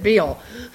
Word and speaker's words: Bell: Well, Ninian Bell, who happens to be one of my relatives, and I pronounce it Beale, but the people over in Montgomery County pronounce it Bell. For reasons that they say it's because George Washington wrote him a letter and actually spelt it B-E-L-L, so they assumed that it must have been pronounced Bell. However, my family pronounces it Beale.